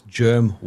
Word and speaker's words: Jerm: So for those people who spell it wrong, it's Jerm Warfare with a J.